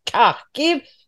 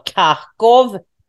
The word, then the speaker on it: Kharkiv: Och han befinner sig i Kharkiv, eller Kharkov. Det finns ju, du vet, ukrainsk och rysk uttal på det.